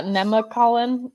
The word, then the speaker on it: Nemacolin: I was going to say, I had to look up too the, I don't even know how you say it. Nemocolon. – Yeah, Nemacolin Estate. – Woodlanders. – Nemacolin.